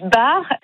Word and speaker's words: Baert: Mon nom de famille, oui, Barre, s'écrit B-A-E-R-T, mais effectivement, on a la même écoute musicale avec Jean-Sébastien Bach, qu'on prononce en allemand Johann Sebastian Bach.